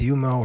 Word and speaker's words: Yoma: Today's Daf Yomi is Yuma, Daf Beis, Yuma, or Yoma, depending on how you pronounce it, too.